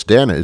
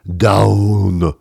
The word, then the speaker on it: Danone: I know it's Danone. It's not down. It's not that.